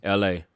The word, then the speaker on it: Elhae: E-L-H-A-E. – The name of the album is Forgotten Flowers. – This Forgotten Flowers. And how you say his name? – L.A. – L.A.